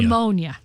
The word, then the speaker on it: pneumonia: Pneumonia. – What's the difference? – Silent P.